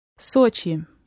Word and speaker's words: Sochi: Sochi. – Sochi. – Sochi. Mm. The vowel is somewhere in between the British English O and O, so that's why it tends to be anglicised as Sochi.